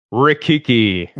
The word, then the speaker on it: Rikiki: Rikiki.